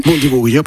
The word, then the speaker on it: Bundibugyo: Jorge, ¿qué diferencia esta cepa Bundibugyo, que no sé si lo estoy pronunciando bien. – Bundibugyo, perfecto.